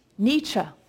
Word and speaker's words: Nietzsche: Nietzsche, thank you.